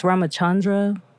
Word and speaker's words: Ramachandra: So Ramakhandra is a Hindu deity. It's pronounced Ramachandra in like the original text, but he has his own sort of, he has like an entire anthology of like stories and like mythical lessons and stuff.